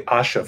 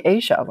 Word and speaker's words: Asha: It's actually Asha Foods, yeah. – Oh, Asha. Okay, yeah. See, I'm saying Asia, like Asia. Asha Noodles. Okay, yeah. Asha. That's A-S-H-A. Asha.